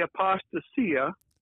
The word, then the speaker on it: apostasia: Yeah, well, I say apostasia, with the accent on the second syllable, and the last S is pronounced like an S, but there is no hard and fast rules for pronouncing Greek.